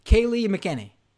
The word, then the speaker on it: Kayleigh McEnany: So one is, I can never say her name, even though she's the White House press secretary, Kayleigh McEnany.